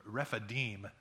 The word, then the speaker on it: Rephidim: Even Google had two different pronunciations. We think it's Rephidim, usually that accent. Alan, you took Hebrew. Is it Rephidim at the end?